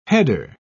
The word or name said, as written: header